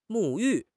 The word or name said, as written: 沐浴